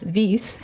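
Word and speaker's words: Wyss: but it's pronounced Weiss, like Edelweiss.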